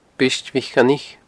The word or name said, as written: Briost Mhic Coinnich